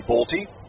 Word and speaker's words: Bolte: By the way, the first time I heard Henry Bolte referred to as Henry Bolt. It was discovered today and want to make sure that I say it properly. The E is pronounced B-O-L-T-E.